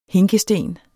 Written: hinkesten

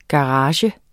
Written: GARAGEN

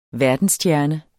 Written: verdensstjerne

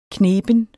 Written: kneben

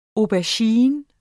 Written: aubergine

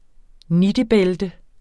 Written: nittebælte

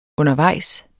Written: undervejs

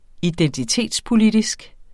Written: identitetspolitisk

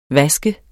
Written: vaske

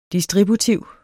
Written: distributiv

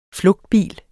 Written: flugtbil

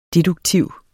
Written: deduktiv

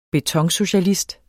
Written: betonsocialist

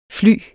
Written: flyer

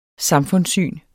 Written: samfundssyn

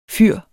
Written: fyr

